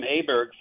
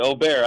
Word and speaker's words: Åberg: We've been calling him Åberg forever, and all of a sudden we were told you pronounce it Aubert.